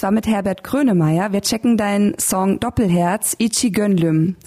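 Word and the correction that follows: İki Gönlüm: Habe ich das richtig ausgesprochen? Äh, Iki Gönlüm heißt er, ja.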